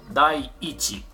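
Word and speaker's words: 第一: 第1 Dai Ichi.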